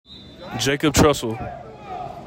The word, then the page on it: Jacob Trussell: JAY kob TRUSS uhl